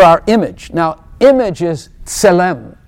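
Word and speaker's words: Tselem (צלם): T-S. Tselem. You have to press your tongue against your teeth. Tselem. Tselem. So T-S is a letter. And that letter in the Hebrew language is pronounced ts.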